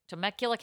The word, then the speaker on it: Temecula: Temecula. Whatever. California. – I've drank there. – Temecula? – Temecula, California.